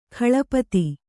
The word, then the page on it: ಕಾಳ್ಪತ್ತಿ: kāḷpatti